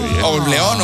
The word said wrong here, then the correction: Kings of Leon: of Lion, no sé cómo se dice, la verdad, porque... – Kings of Leon.